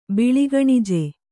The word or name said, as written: ಬಿಳಿಗಣಿಜೆ